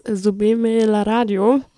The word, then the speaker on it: Súbeme la Radio: Und genau, es ist Subime la Radio.